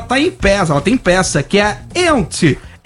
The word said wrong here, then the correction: Yentl: Out in Concert. – Yentl. – Eu vou fazer com pronúncia Yentl.